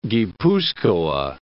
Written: Guipuzcoa

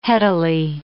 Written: headily

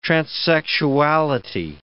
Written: transsexuality

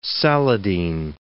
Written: Saladin

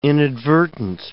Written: inadvertent

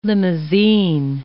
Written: limousine

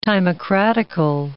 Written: timocratical

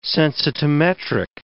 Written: sensitometric

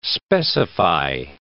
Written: specify

